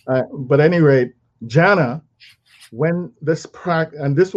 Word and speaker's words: dhyana: Now, dhyana is spelled D H Y A N A, dhyana, which means mindful contemplation.